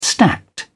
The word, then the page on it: stacked: British English